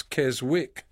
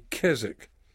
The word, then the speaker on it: Keswick: You pronounce it sometimes Keswick, but it's actually Keswick.